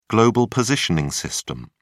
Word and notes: global positioning system: British English

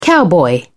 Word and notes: cowboy: British English

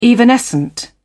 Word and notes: evanescent: British English

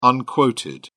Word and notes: unquoted: British English

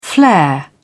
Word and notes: flair: British English

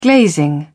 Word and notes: glazing: British English

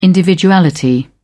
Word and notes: individuality: British English

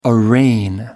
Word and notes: arraign: American English

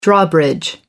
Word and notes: drawbridge: American English

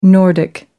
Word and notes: nordic: American English

